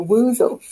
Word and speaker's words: woozle: And one of those would be a woozle. – Oh. Woozle was some sort of a creature that he was scared of um, in the books, and it rhymes with croozle.